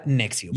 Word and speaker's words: NXIVM: Se escribe N-X-I-V-M. Voy a llegar a por qué se llama así. – Por significa? Mamar. – Pero se pronuncia Nexium.